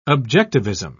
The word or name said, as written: objectivism